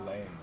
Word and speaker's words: Lange: Jason, how do you say your last name? – Lane. L-A-N-G-E, yeah.